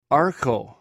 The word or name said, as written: arcw